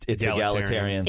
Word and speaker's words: egalitarian: And I hope I'm pronouncing this correctly. – Oh, boy. – Egalitarian men. – It's egalitarian.